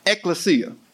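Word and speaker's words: ekklesia: It's pronounced ecclesia.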